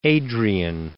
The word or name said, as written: Adrian